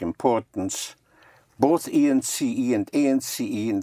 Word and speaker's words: importance: Importance. – Importance. – Importance. The IM and IN importance. Is un.